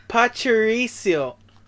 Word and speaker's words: Pachirisu: Not your chorizo. It's Pachirisu.